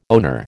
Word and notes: owner: American English

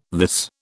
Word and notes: this: British English

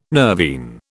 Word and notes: nervine: British English

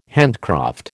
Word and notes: handcraft: American English

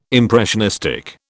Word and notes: impressionistic: British English

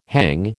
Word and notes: hang: American English

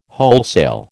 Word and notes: wholesale: American English

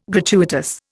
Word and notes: gratuitous: Indian

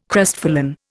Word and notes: crestfallen: Indian

